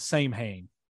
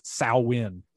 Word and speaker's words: Samhain: And it looks like it's spelled Samhain, right? Yep. But it's actually not Samhain. It's Samhain. You